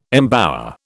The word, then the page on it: embower: British English